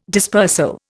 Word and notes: dispersal: Indian

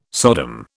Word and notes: Sodom: British English

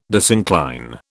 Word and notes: disincline: British English

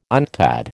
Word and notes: UNCTAD: American English

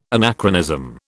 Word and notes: anachronism: British English

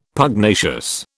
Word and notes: Pugnacious: British English

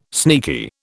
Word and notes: sneaky: British English

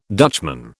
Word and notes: Dutchman: British English

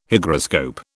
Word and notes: Hygroscope: British English

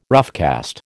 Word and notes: roughcast: American English

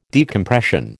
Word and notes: decompression: American English

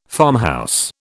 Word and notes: farmhouse: British English

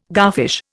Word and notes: garfish: Indian

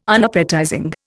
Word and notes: Unappetizing: Indian

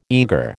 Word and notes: eager: American English

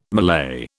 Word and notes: Malay: British English

